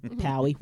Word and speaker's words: Powe: People always want to be Powie or Poe or Mama, and I'm just like, so how do you say L-O-W-E? Low, R-O-W-E, row, P-O-W-E, Powie. Why? – Cool whip. – So the same thing with Battelle. I'm like C-A-T-T-L-E.